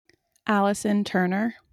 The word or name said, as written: Turner